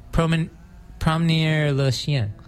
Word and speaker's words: Promener le Chien: But we heard the song, which in English translates to walking the dog, Promener le Chien. – That's good. That's good. That's good pronunciations.